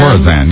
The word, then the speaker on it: Khorasan: Khorasan, yeah.